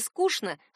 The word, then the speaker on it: скучно: Если вам кажется, что в этом двустишии нет рифмы, значит, наречие «скучно» вы произносите не совсем верно. Если следовать литературной норме, «скучно» будет звучать именно как «душно», «скучно». Словари отмечают, произносить это слово через «че» не рекомендуется. «Скучно», «душно», «скучный», «душный».